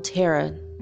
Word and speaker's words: Terra: Or, I'm sorry, he said Terra, right? Yeah, okay. Terra, T-E-R-R-A. I saw him say that, but Tierra is how you say Earth in Spanish, so I just said it wrong.